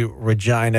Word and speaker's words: Regina: people say regina is the city that rhymes with fun so the tourism board took advantage of that and mm.